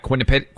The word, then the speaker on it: Quinnipiac: Quinnipiac. – How do you pronounce that? Quinnipiac. Oh, yeah.